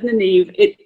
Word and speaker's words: Nyneve: Actually, I just realized, like, I said Nynaeve. When I originally came up with it, it was Nynaeve, but I'm trying lately to shift it to Nineveh, because that's, like, a more, like, accurate to the setting pronunciation of the name. So, just replace me saying Nineveh just then and just, like, edit that into Nineveh.